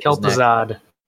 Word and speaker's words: Kel'Thuzad: I don't know how to pronounce it. – Kel'Thuzad. – Kel'Thuzad. Okay.